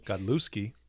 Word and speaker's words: Godlewski: Godlewski. – Is that how you say it? – It's a G, yeah.